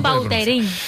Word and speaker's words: Vautrin: La original francesa, Vautrin. – Ahí pega un Vautrin. – A mí me gusta Vautrin.